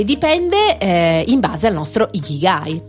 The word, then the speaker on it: Ikigai: E quindi questo nome giapponese, a quanto pare dai nostri studi fatti in questi due minuti, si pronuncia Ikigai. – Ikigai.